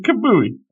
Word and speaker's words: Kamuy: That's how you say it. It's Golden Kamuy.